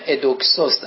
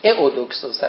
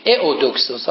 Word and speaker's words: ایودوکسوس: البته خودش ندیده بود، یک رفیقی داره، دوستی داره به نام ادوکسوس، البته اسم دقیق‌ترش ایودوکسوس هست چون ایو در وانده خونده میشه و در زبان یونانی باید تلفظ بشه ایودوکسوس، حالا در فرانسه و انگلیسی ممکنه بندازن این یورو.